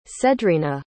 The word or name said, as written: cedrina